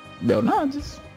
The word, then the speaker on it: Belnades: É Belnades que se pronuncia? Belnades.